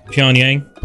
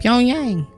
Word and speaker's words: Pyongyang: Pyongyang. – Wow. Yeah, did. – Pyongyang. Is that P-E-O-N-Y-A-N-G? – No, it's P-Y-O-N-G. Nice. Y-A-N-G, nice.